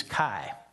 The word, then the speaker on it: kai: And so the word and in Greek is kai. We would spell it K-A-I.